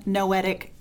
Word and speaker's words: Noetic: So noetic rhymes with poetic. Sometimes people have trouble pronouncing it.